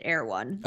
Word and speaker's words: Erewhon: It's spelled really weird, but everyone pronounces it Erewhon.